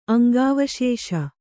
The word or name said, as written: ಅಂಗಾವಶೇಷ